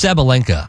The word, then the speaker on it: Sabalenka: Isn't it Ariana Sabalenka? – Yes, it's Sabalenka.